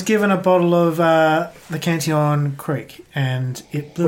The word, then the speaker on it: Cantillon: And Cantillon is obviously, you've probably heard of it. It's quite a very well-renowned. – I think the pronunciation is Cantillon. – Cantillon. – No one say that ever again.